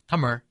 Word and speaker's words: Tamil: That's Tamil spelled T-A-M-I-L in English. People will often pronounce it Tamil. In Tamil, it's actually pronounced Tamar. – Right. – So I usually say Tamil because that's about that seems right to my ear.